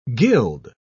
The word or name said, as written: guild